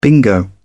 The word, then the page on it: bingo: British English